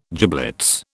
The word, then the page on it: giblets: British English